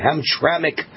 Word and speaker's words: Hamtramck: First of all, a Michigan City Council in all Muslim Hamtramck, that's how you pronounce it, Hamtramck, Michigan.